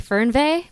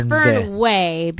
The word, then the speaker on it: Fernweh: So in German, it'd be Fernweh, but it's Fernweh because we're in America and it's easier that way and it's less confusing. Plus, Fernweh Food Company, the alliteration just kind of works.